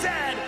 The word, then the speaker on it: Zed: The toque is a hat, the Chesterfield is a coat, and it is pronounced Zed, not Z-Z.